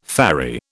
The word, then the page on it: ferry: British English